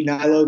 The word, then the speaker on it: Hnilo: So I'm at Andy Nilo. That's A-N-D-Y, last name H-N-I-L-O. The H is silent, obviously. And then it's at Alitura Naturals. That's A-L-I-T-U-R-A-N-A-T-U-R-A-L-S.